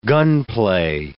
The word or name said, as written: gunplay